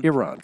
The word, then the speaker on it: Iran: Am I saying it right? I'm not one insulting nobody. Iran? – Iran, that's correct.